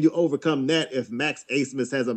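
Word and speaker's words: Abmas: If anybody remembers previous tournament performances from Max Asemus, and make sure you get that pronunciation right because it's spelled with a B for some reason, but Max Asemus can score.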